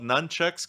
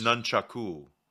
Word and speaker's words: nunchaku: Nunchaku. No, nunchaku. It's not nunchucks though.